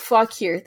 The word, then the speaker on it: Fauquier: Fauquier? Yes. Yes, you know what? Fauquier. That sounds right. – You can say Fauquier. Oh my god, the Glasscock's Fauquier. – The Glasscock's Fauquier!